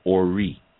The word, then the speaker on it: Ori: Okay, one of the consciousness that were created was called Ori, which is spelled O-R-I, Ori.